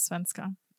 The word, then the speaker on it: svenska: That's how you say Swedish in Swedish, is svenska. – All right, that's what I'm calling it from now on. – Svenska.